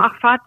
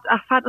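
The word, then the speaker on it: Ahfad: Wie heißt die äh, korrekt ausgesprochen? Achfat? Universität? Genau, Achfat, Achfat Universität und die wurde gegründet von jemandem,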